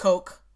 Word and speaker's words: Koch: I know it's pronounced Coke, but if I say that, it's going to be weird because this is an audio format. So we're saying Koch.